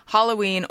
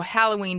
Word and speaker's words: Halloween: I looked it up today in American Heritage Dictionary, and it says either pronunciation, it has either pronunciation, so either one is acceptable, although Halloween does come first.